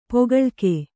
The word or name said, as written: ಪೊಗಳಿಕೆ